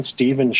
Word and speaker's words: Stephen: And if you forget, then the URL to the website, if you type in my name, Stephen Shore, that's spelled with a PH, and the word autism after it, then my website will pop up pretty quickly on Google.